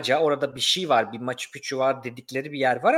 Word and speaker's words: Machu Picchu: Machu Picchu okunuşunda iki ikinci yani Machu Picchu ikincisinde iki Ç varmış. İki Ç evet. Evet, Keçuva dilinde bir şeymiş bu.